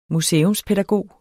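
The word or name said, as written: museumspædagog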